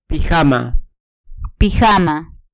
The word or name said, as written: pijama